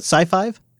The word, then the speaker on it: SiFive: SiFive Yes, SiFive, I think is how you pronounce it.